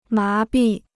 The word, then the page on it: 麻痹: má bì